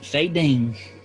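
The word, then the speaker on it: Faydeen: Faye Dean. – Faye Dean. Okay. How do you spell that, DM? Faye Dean. – F-A-Y-D-I-N-E. Actually, F-A-Y-D-E-E-N. F-A-Y-D-E-E-N. Faye Dean.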